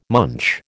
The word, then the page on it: munch: American English